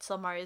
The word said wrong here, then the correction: Stelmaria: is giving him these, or Stelmaria, sorry.